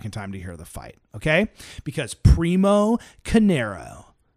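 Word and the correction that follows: Carnera: did I say his name right? No. Primo Canera, nicknamed the Ambling Alp, was a six foot five and a half be- foot beast.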